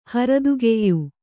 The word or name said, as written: ಹರದುಗೆಯ್